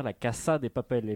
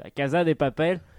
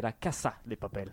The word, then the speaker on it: La Casa de Papel: En espagnol, ça se prononce bien La Casa de Papel, et pas non comme tous les Français qui disent La Casa de Papel. Faut arrêter, c'est La Casa de Papel.